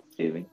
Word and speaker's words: Tubi: I've been calling Tudu? It Tubby. – Oh, it's Tubby.